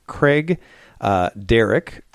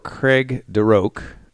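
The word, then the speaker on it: Craigdarroch: Now there's a couple ways to say this because I heard a couple different ways. Craig Derrick or Craig Deroque. And it's Craig, like you spell it with a C, Craig Darroch castle.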